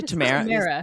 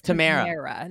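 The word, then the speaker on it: Tamera: Tamara Mori was on, did I say Tamara? – Her name is Tamara, not Tamara.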